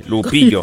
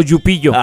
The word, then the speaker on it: Lupillo: Con doble L. Ajá. – Lupillo. – No con Y. Sí, porque luego hay quienes lo quieren pronunciar como Yupillo.